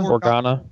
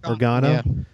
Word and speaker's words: Organa: As Bail, how do you say his last name? Organa? – Organa. – Organa.